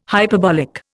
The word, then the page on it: hyperbolic: Indian